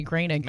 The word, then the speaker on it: Groening: It looks like it's spelled Ocasek, right? Like Matt Groening or Groening, and it's actually Groening.